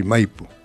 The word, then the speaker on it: Maipú: O sea que nosotros lo pronunciamos así, Maipú. – Maipú. Como el cajón del Maipo.